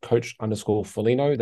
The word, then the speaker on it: Folino: And you can find me on Instagram at coach_Folino. That's F-O-L-I-N-O.